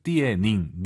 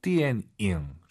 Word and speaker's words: 电影: 3월부터 저희와 함께 하신 분들은 영화 발음 이제 아실 것 같아요. 띠에닝, 니은 발음 들리면 안 돼요. – 电影. – 띠엔잉.